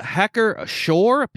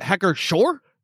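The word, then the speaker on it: Hacker-Pschorr: It's a Hacker Shore, P-S-C-H-O-R-R, Weiss beer. – All right. – But that's, is it Hacker Shore? For sure? – For sure.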